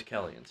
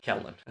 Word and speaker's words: Kellyn: K-E-L-L-Y-N. It's after her mom. Her mom name was Kelly and stuff, you know. – Kellyan. – Kellen.